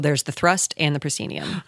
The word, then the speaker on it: proscenium: Currently can be seen in the Guthrie's The Royal Family, which runs, tell me how to say the word, proscenium.